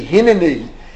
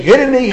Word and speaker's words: Hineni: Again, I'm pronouncing it the way it should be pronounced, although people generally say Hineni. They say Hineni ha'ani mimas.